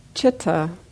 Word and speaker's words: citta: Citta rhymes with metta.